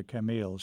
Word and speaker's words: Camille: I think you're talking about my wife's niece, Camille, or as we would pronounce the name in America, Camille, C-A-M-I-L-L-E, who, I'm almost certain that's what you're thinking of.